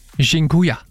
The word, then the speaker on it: Dziękuję: Og en af dem, som jeg har brugt rigtig meget, det er det, der hedder, undskyld min udtale, Dziękuję.